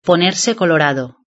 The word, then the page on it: ponerse colorado: European Spanish